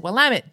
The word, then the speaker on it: Willamette: She said, because people always think it's Willamette or something along those lines, because how it's spelled, you wouldn't think to say Willamette. But the trick is Willamette, damn it.